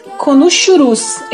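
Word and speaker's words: Konuşuruz: E a pronúncia do nome da banda seria algo mais ou menos parecido com Yuzyuzinken Konushurus. Esse S com uma cedilha no S tem um som meio chiado. Konushurus. Sim.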